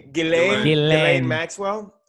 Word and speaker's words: Ghislaine: And I thought it was going to be like conspiracy Reddit because the meme was like about Ghislaine Maxwell. Did I say her name? I don't think you said yes. – Ghislaine? – No S. – Ghislaine Maxwell.